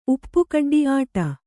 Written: ಉಪ್ಪುಕಡ್ಡಿ

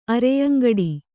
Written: ಅರೆಯಂಗಡಿ

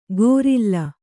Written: ಗೊರಿಲ್ಲ

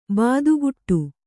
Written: ಬೆದೆಗೂಡು

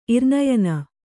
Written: ಇರ್ನಯನ

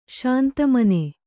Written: ಶಾಂತಮನ

